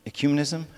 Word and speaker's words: ecumenism: Ecumenism? How do I say that? Say it. Ecumenism.